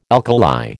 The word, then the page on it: alkali: American English